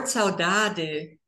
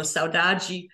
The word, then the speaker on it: saudade: The word saudade, or, you know, pronounced in European Portuguese, or saudade in Brazilian Portuguese, is a very unique word that is hard to translate.